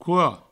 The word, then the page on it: coa: couo